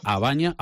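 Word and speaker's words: A Baña: María Varela, un abrazo muy fuerte, feliz regreso a tu tierra, esta compostelana vecina de Abaña. Ahora sí lo he dicho bien, ¿verdad, María? – Sí.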